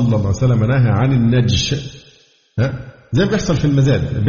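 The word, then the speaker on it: النَّجْش: النوع الثامن من البيوع المنهي عنها النجش بتسكين الجيم، لا كما ينطق بعض الإخوة النجش، لا النجش، النجش بتسكين الجيم، وهو أن يزيد شخص في ثمن السلعة المعروضة للبيع ولا يريد شراءها، وإنما ليغر غيره بها ويرغبه فيها ويرفع سعرها.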